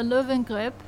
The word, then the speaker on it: Löwengrip: recording a live podcast on stage with Isabella Löwengrip. Yes. Uh, did I pronounce that correctly? – Yeah, yeah that was pretty good.